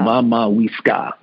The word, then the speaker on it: Mammaweska: And we have a tendency to Weska. It's Wiska. Wiska. It's likely Wiska. Mama Wiska.